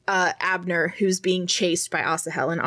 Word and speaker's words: Asahel: Okay, so it is Asahel. It's better than Asahel. Asahel, that's better. Okay, so Asahel.